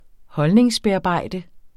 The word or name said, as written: holdningsbearbejde